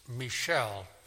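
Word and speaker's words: Michal: Now, it's spelled Michael, but it's Michelle.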